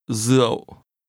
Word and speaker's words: rượu: Rượu. Rượu vang. Rượu vang. – This is pronounced with a low, falling, broken tone.